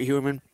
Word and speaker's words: Heuerman: No, bottom line is I can't make an argument for Heuerman unless you're really, really desperate at tight end. – And I will apologize, I mispronounced Jeff Heuerman. So H-E-U-E is high somehow.